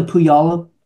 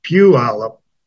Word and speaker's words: Puyallup: Well, that's the spelling, but it's pronounced backwards. It's Puyallup. – Puyallup. Thank you very much. – That's a lot of people make that, particularly people not from the area. – Particularly Californians. – Yeah, make that mispronunciation all the time because that's how it's spelled, but it's Puyallup.